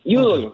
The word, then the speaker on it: Udall: Yudol, con acento en la U.